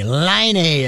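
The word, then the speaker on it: Laine: 44 goals, but is uh, Patrick without the C and he, he it's Patrick because I had to go to a pronouncing guide, mm-hmm. and Line A. Line A is uh, how they told me how to pronounce it.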